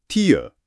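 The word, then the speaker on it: tear: 名詞の涙、これはティアでも発音がティア。 – そうですね。 – 今日やる動詞のテアとはもう全然違う音なので、まずこれをしっかり区別しましょう。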